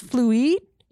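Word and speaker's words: Fluide: I believe it is pronounced Fluid. It's like fluid with an E, so it's fancy.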